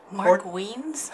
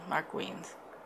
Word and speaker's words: Wiens: So, yeah, Mark Wiens. – Nice. – Mm-hmm. – Wiens spelled like Wiener? – W-I-E-N-S, I think.